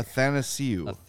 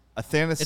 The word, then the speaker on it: Athanasiou: Athanasiou. – It's like. – Athanasiou. – It's like Nathan without the N.